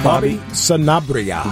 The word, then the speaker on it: Sanabria: Bobby Sanabria.